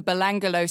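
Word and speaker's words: Belanglo: As the duo approached the Belangolo State Forest, which I remember how to say it because it's like a bungalow on an angle.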